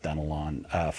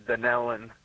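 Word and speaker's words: Dunnellon: I'm going to go with Dunelon, Florida, watching on YouTube. I hope I said the town right. I have not heard of that town before. – No, actually, it's pronounced Dunelon.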